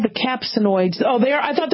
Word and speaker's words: capsaicinoids: Capsaicinoids. Oh, like capsaicin. Yeah, I pronounced that one right. Capsaicinoids.